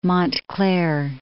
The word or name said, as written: Montclair